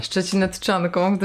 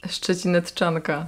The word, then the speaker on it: Szczecinetczanka: Szczecinetczanką, gdyby ktoś nie wiedział, jak się wymawia to słowo. – To jeszcze raz powtórz. – Szczecinetczanka.